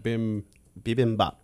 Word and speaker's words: bibimbap: Bibimbap. – There you go. – Bibimbap.